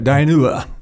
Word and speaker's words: Dinuba: He took advantage of his VA benefits and put himself through dental school, then moved his family to Dinuba. Am I saying that right? – That's right, Dinuba.